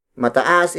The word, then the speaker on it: mataas: Taas is spelled as T-A-A-S. When used as an adjective, it becomes mataas. Mataas. Mataas. Mataas is spelled as M-A-T-A-A-S. It means high or tall. Remember that adding the M-A prefix to a root word turns it into an adjective. Mataas is used to describe tall or high objects.